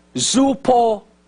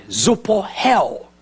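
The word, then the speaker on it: zoupohel (ζωοποιέω): The word quicken is the Greek word zoupohel. That's how they say it, zoupohel.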